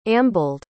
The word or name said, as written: ambled